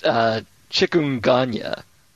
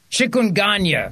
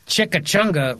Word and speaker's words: chikungunya: It's chikungunya. – Chikungunya virus in Guangdong. I got your Guangdong right here. I got your Guangdong fever right here. How you doing? You want a little of this Guangdong fever? That's what they should call it. Guangdong fever. Not chikachunga. What is it? Chimichunga? – I think it's pronounced chikungunya.